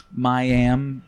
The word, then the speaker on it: Mayim: Can you say her name a little slower? – Mayim Bialik. – That sounds like a rapper, like YNB Melly or whatever. – Yes, Mayim Bialik. Yes, sir. Mayim? M-A-Y-I-M? – I've always heard it as Mayim Bialik.